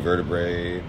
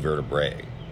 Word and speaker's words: vertebrae: And he hadn't broken his beer, but he'd broken two vertebrae. How do you say that word? Vertebrae? – Yeah. – Vertebrae in his neck. – Wow. – Vertebra. – Vertebra? – Oh, so it's plural. Vertebra is plural and vertebrae is singular.